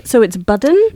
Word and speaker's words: Budden: But Mr. Button, that's pretty sick, too. Like Benjamin. – Oh, so it's Budden? – B-U-D-D-E-N. – Okay. Yeah. Well, then I also mispronounced your name.